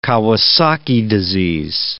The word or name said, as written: Kawasaki disease